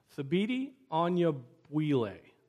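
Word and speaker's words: Thabiti Anyabwile: Thabiti Onyobwile. How do you say that name? Who can say that name? Thabiti Onyobwile. That's it. Thabiti Onyobwile.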